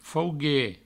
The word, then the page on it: fauguer: faugue; Provençal